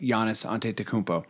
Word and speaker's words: Giannis Antetokounmpo: We we can't have a podcast this early in the season without talking about Giannis Antetokounmpo. Um, Beautiful pronunciation, by the way. Yeah, yeah you nailed that. Did I nail it? You did nail it.